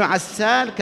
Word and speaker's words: عسال: وابن عسال كذلك كما تسمعون النطق، عسال عين فسين فلام ألف فلام بعدها.